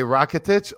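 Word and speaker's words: Rakitić: Sir, did I say that right? Rakitic. Right? Okay, Rakitic. Okay.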